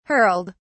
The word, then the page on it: hurled: British English